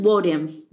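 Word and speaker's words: Nwodim: It's just Wodum. Wodum. Egon Wodum. Oh my gosh, I said the N again. Sorry. There's a silent N at the beginning of her last name.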